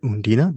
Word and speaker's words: Undine: So we covered Phoenix, we covered Undine, as Eric likes to put it, Undina, because that's how they pronounce Undine in the movie.